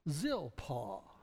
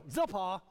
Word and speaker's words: Zilpah: And then we shall pronounce the biblical name of Zilpah. I like him. He says, you know, most of them go, Zilpah. It's like, what?